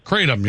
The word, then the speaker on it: Kratom: There's no way that is it pronounced Kratom? – Kratom, yep. Some pronounce it Kratom, but I'm convinced it's Kratom.